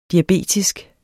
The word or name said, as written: diabetisk